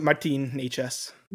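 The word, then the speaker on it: Nečas: Martin, I always say his name wrong. Natchez? – Yeah, you can get Martin Natchez. – Martin nailed it.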